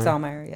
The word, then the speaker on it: Zaumeyer: Zaumeyer, yes.